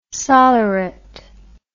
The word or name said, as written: solleret